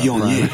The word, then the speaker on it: Viognier: Most of the show is about Viognier.